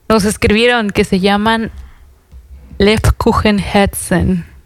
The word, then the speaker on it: Lebkuchenherzen: Lebkuchenherzen.